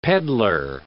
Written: pedlar

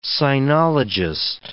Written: sinologist

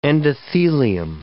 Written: endothelium

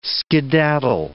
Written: skedaddle

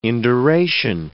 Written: induration

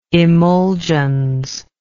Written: emulgens

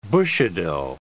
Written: Bushido

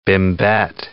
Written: bimbette

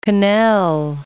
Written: quenelle

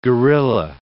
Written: guerrilla

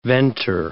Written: venter

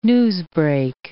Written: newsbreak

